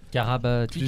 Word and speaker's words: Karabatic: Karabatic. Karabatic. Je prononce bien. Ouais.